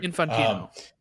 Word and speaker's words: Infantino: Infantino.